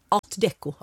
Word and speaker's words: Art Deco: är ADK, förlåt? – Art Deco. Förlåt att jag snubbade till mig med det franska uttalet.